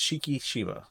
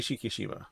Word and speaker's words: Shikishima: I want to say, Kiyoshi Shikishima. Yeah, it's Shikishima. Kiyoshi Shikishima.